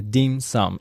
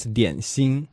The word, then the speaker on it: dim sum: That's actually the pronunciation from Cantonese for dessert. It's a dim sum. In Mandarin, it's dian xin.